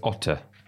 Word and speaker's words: Otter: We've got a question here from Nicholas Otter. I believe the E is pronounced in German.